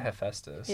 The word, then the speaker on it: Hephaestus: Is that how it's supposed to be pronounced? – That's how I like to pronounce it. – Okay. – That's how I've always pronounced it Hephaestus. – Yeah, I have too. – Okay. – I mean, I've heard it from Hephaestus, but I like Hephaestus. It just sounds elegant.